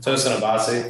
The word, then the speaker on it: Tosin Abasi: Tosin Abasi.